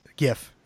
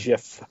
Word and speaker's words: GIF: Oh, when they said they had jerseys and everything and they used the Taylor GIF. – GIF. It's GIF, thank you. – GIF. It's a GIF. Soft J.